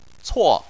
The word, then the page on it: cuo: 4